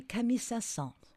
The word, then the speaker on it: Saint-Saëns: It looks to me like Saint Sen, but I don't know that that's correct. Anyway, and he's got this incredibly difficult first name, Camel or something. Do you know how to pronounce that, dear? – That would be Camille Saint-Saëns.